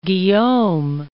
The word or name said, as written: Guillaume